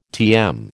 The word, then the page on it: TM: American English